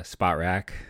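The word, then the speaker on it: Spotrac: So I went over to Sport Track. I always say it wrong. It's not Sport Track, Spot Rack.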